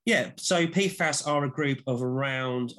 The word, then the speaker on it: PFAS: PFAS? – That's how we pronounce it. There are, I think, various other pronunciations, but we start with PFAS.